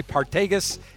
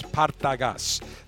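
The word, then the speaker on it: Partagás: A lot of people say Partagas. It's not. It's Partagas. That's the proper pronunciation as I toast the foot of this beautiful cigar.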